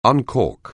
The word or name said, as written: uncork